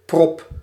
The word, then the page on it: bouchon: France; [bu.ʃɔ̃]